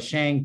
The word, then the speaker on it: Shang: And there is a very definite attempt by people in China to make sure that you pronounce it correctly. It's Shang, as in Shanghai. So I'm glad you picked up on that, Chris, because you are not going to be one of those Shang people that are going to be causing us trouble here in the movie theater.